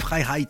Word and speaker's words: Freiheit: Holger Ben, c'est son vrai nom, et Freiheit, c'est euh, la vraie prononciation du label.